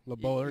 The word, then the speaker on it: LaBeouf: LaBeouf, there you he, go.